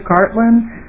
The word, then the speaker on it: Gartland: Mr. Chair, members of the committee, I'm Patty Gartland. That's G-A-R-T-L-A-N-D, and as indicated, I'm the Sartell City Administrator.